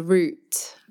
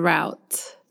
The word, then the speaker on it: route: I would say, you know, the route, not the route. – That is interesting. A root for us is like a beet or a ginger, a root vegetable. – A root vegetable. Yeah. Our pronunciation of the two words, although they're spelled differently, is the same.